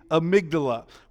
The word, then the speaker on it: amygdala: It's A-M-Y-G-D-A-L-A. A-M-Y-G-D-A-L-A. It looks like amygdala, but it's not. It's amygdala.